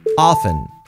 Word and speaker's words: often: Often. A menudo. Often. También se puede decir often, pronunciando la T. Pero si no, often.